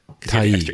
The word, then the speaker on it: Tye: Tai. – It says T-Y-E. Yeah. Tai. So I don't know what a Tai is, though. – I don't know what a Tai is either.